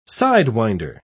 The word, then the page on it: sidewinder: British English